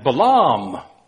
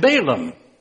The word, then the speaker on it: Balaam: The Hebrew pronunciation of the names of our revealing Old Testament characters today are Balak and Balaam. But you are probably familiar with the Americanization of those names, Balak and Balaam.